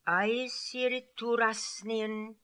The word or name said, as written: Aiessirittoorassnian